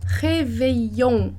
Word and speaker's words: réveillon: Esses dois L's a gente vai pronunciar com o som de I. Outra palavra que você já conhece, que é réveillon, réveillon, réveillon, com som de I.